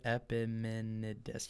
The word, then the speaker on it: Epimenides: Epimenides.